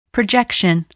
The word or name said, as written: projection